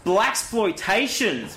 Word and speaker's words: Blaxploitation: Or it's, I apologise if I get this word wrong. Or it's blaxploitation. – Blaxploitation! – Spelt with an X. – You did not just invoke the blaxploitation. He's playing the blaxploitation card! How fucking awesome is it that I pronounced that right 3 times in a row?